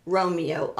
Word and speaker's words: Romeo: Beverly, B-E-V-E-R-L-Y, Dubaz, D-U-B-A-Z, Romeo, R-O-M-E-O.